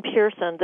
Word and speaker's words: Pearson: my website is Elayne Pearson.